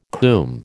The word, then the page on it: combe: American English